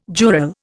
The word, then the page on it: jural: Indian